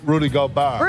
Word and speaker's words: Rudy Gobert: Rudy Gobert.